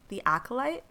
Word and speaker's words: The Acolyte: The Acolyte? Is that how you say it? – Yeah.